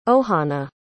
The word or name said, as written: ohanna